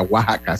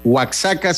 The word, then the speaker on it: Oaxaca: Es Oaxaca, Oaxaca, sí se le. Oaxaca se pronuncia Oaxaca.